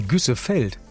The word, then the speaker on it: Güssefeld: Um Winter-Güßfeld, ich hoffe, ich habe es richtig ausgesprochen. Ja, bestimmt. – Da haben wir aktuell, nee, Güssefeld.